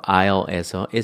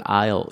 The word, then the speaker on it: aisle: 여기서 그 aisle에서 s가 묵음인 거를 얘기 안 했네요. 그냥 꼭 쓰세요. s 묵음이라고 쓰세요. 이걸 aisle 이렇게 읽어야지 aisle 이렇게 읽으면은 참 웃깁니다.